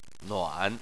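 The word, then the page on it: nuan: tone 3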